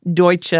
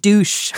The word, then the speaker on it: Deutsche: At least you had to learn to pronounce Deutsche like that and not like douche, right? Which was the early American pronunciation.